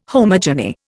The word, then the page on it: homogeny: Indian